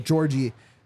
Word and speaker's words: Djordje: You have Djordje, who went from one of the best assisters, period, in the MLS, yep. goes over to AZ Alkmaar and... – Oh, good. Good pronunciation.